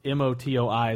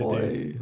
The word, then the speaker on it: Motoi: Uh, fun modern note is that uh, Motoi Sakuraba I keep feel like I'm butchering that name because M O T O I is a hard English thing to do. Motoi.